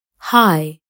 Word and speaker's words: high: ويمكن أيضا أن يصبح صامتا لما تأتي جي اتش بعد حرف متحرك مثل high daughter though